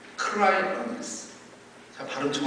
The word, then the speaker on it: cryonics: cry earnest. 발음 정확히 했습니다. C-R-Y-O-N-I-C-S Cry Earnest.